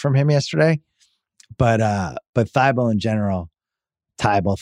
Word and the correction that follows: Thybulle: Thybulle. Sorry, pronunciation dyslexia.